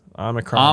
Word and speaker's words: Omicron: Speaking of mitigating the bad, Omicron. Did I say that right? – Yes, Omicron.